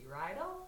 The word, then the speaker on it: Rydell: And I'm Kelsey Rydell. My last name rhymes with bridal.